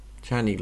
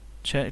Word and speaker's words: Cerny: Äh, Mark, wie heißt der eigentlich, wie spricht man das eigentlich aus? Czerny? Cerny? Czerny, glaube ich. Czerny, glaube ich auch.